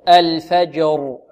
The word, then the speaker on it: الفجر: إذن ننطق هكذا: الفج الفج، انطقوا معي الفج الفجر الفجر. – والراء حكمها ماذا؟ التفخيم. لماذا؟ لأنها ساكنة، وما قبلها وما قبل الساكن مفتوح، حكمها التفخيم.